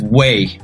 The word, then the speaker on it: wey: Well, everybody says way, W-E-Y. It's like dude down here. Say way. – Way. – Way. – Way. – Like the way. Like you pronounce it like way.